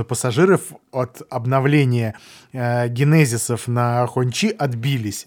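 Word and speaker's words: Hongqi: Ну, коли речь идет за роскошь, то красное знамя, Хончи, я правильно сказал? – Правильно, но надо еще поработать над произношением.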